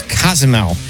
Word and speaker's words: Cozumel: Kazumel? – Yep. Like, actually, oh, my God. – The correct pronunciation is Cozumel.